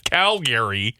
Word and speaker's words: Calgary: See, I'm being made fun of by listeners because I pronounce the city the way you've told me to say it, Calgary.